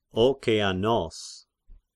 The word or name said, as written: Okeanos